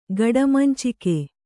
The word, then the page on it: ಗಡಮಂಚಿಕೆ: gaḍamancike